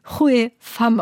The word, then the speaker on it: Hue Pham: Und da hat sie mir netterweise eine Audiodatei geschickt und deswegen wissen wir jetzt schon vor dem Interview, dass sie Hue Pham ausgesprochen wird.